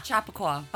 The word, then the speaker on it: Chappaqua: Chappaqua.